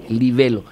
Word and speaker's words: libelo: Antes de entrar en los pormenores del libelo acusatorio, porque así se dice, no es libelo, es libelo, discúlpame yo, a veces, libelo. El acento en la E, libelo.